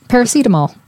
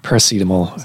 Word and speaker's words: paracetamol: The lower panel, I can never pronounce, what is it? – Paracetamol. – Paracetamol.